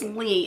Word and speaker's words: Lea: And Leah, not Leah, sorry, I'm sorry. Her name is Lee. It's spelled L-E-A, but pronounced Lee.